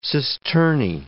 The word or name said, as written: cisternae